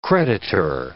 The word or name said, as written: creditor